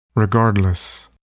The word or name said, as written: regardless